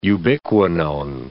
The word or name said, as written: ubiquinone